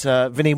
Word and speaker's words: Vinney: You can follow me on Twitter at Vinny White. V-I-N-N-E-Y, White.